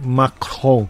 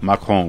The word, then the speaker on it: Macron: Pronuncia de novo o sobrenome do Emmanuel. – Macron. – Macron, oui, c'est ça.